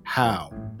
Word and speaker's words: Hough: I would like someone to answer for why my last name is spelled H-O-U-G-H, but pronounced How. All the famous H-O-U-G-Hs pronounce it Huff, like Julianne Huff, the TV dancer and singer, and Charlie Huff, the former knuckleball pitcher.